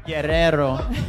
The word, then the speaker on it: Guerrero: Sorry, I can't really pronounce the name, Guerrero. And Sessions crew. Guerrero. Gotta roll the R's.